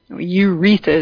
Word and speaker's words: urethra: It's called the urethelium, and it is a layer that lines the inside of the bladder and also the urethra, or urethra is how it's pronounced, which, you know, is the ductwork in which the urine passes from the kidney to the bladder.